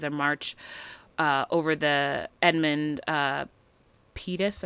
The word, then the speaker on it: Pettus: I think it's Pettus. It's Pettus. Oh, Pettus.